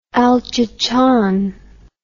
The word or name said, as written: El Chichon